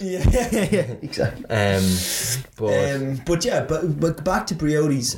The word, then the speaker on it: Briody's: He was telling us to go to Briody's, and the way he pronounced it was Briody's.